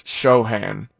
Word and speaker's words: Sochan: I've heard it Sochan, and I've Sochan? Heard it Shane. – I think it's Sochan. It's spelled Sochan.